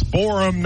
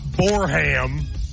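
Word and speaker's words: Borehamwood: Myron, we have a listener who is from Boreham Wood. – Oh, yeah? – They said, Matt, your pronunciation is off. It's Boreham, not Boreham.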